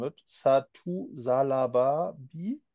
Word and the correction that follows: Sabali: Sabali. Sabali. Satu Sabali.